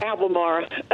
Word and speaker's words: Albemarle: Albemarle.